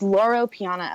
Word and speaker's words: Loro Piana: And it's Laura Piana, L-O-R-O-P-I-A-N-A.